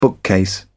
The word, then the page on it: bookcase: British English